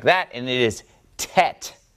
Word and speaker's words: tet: It is the ninth letter of the Hebrew alphabet. I just remember that it follows chet, so it goes chet, tet, chet, tet. And they rhyme with one another, so that makes it easy to remember that one.